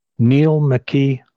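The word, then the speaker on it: Neill McKee: My name is spelled NEILLMCKEE, Neil McKee.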